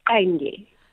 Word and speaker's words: Qenge: Amandla, you know, because she's Xhosa and she can pronounce Qenge the way, you know, it's pronounced in Xhosa.